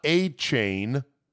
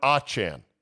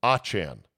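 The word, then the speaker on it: Achane: Achan. Not A-Chain, but Achan. This after his four-touchdown performance on Sunday. He told the Miami Dolphins to make sure everyone knows that he wants his now name pronounced Devon Achan, not A-Chain.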